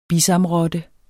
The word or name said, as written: bisamrotte